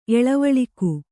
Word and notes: ಏಲಾವಾಲುಕ: kannada

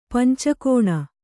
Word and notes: ಪಂಚಾಗ್ನಿ: pancāgni

